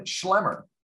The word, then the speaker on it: Schlemmer: Next, we'll have Kristen Schlemmer. Her last name is spelled S-C-H-L-E-M-M-E-R.